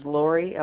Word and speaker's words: Lori: First name is Lori, L-O-R-I. Last name is Slattery, S-L-A-T-T-E-R-Y.